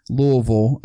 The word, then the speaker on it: Louisville: It's Louisville, I guess I should be pronouncing it.